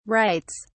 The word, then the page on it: writes: British English